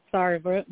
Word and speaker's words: Sarver: Sarver.